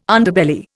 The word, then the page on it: Underbelly: Indian